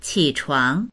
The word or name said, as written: qi3chuang2